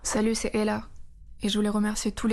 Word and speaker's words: Ehla: Elle s'appelle Ella, ça s'écrit E-H-L-A.